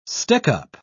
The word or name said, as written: stickup